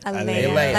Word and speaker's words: Aaliyah: Did I say it right or did I mess it up? Aaliyah. – Aaliyah. Like Princess Leia.